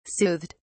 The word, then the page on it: soothed: British English